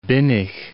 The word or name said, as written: Binnig